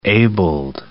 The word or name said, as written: abled